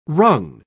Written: wrung

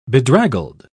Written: bedraggled

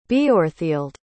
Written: Beorhthilde

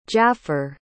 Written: jaffer

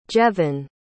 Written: jeven

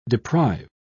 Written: deprive

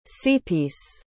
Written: seapiece

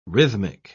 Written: rhythmic

